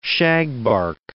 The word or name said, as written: shagbark